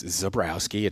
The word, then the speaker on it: Zebrowski: Yeah, for some reason, people put an M in my name, and I've always wanted to ask a linguist why they would do that. It's Zebrowski. It's pretty phonetically correct. It's certainly a, a Polish ethnic last name, but they put a Zimbrowski in it, and I don't know why people do that, and they've done that for my entire career.